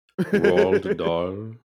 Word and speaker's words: Roald Dahl: And how do you say his name? Roald? Rod? – Roald Dahl. – Roald Dahl.